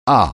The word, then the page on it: ah: français